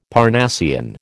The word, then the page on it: Parnassian: American English